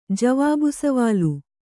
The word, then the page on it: ಜವಾಬ್‍ಸವಾಲ್: javāb savāl